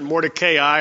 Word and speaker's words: Mordecai: His name is Mordecai. You might pronounce it Mordecai. A lot of the times when you see the A and the I together in Hebrew, they're both long.